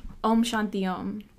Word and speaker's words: Om Shanti Om: Om Shanti Om.